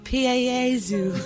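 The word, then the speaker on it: Pie Jesu: It's Piezu. – Which is spelt pie. – Yeah. – So it's Pie Jesus.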